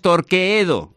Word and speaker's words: Torqueedo: Atención, porque ha dicho, me lo ha dejado claro David, oye, que se pronuncia Torqueedo, que no es Torqueedo, se escribe Torqueedo, pero en realidad se pronuncia Torqueedo.